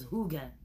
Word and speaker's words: hygge: And one of the words we came across was Huge. It's spelled H-Y-G-G-E.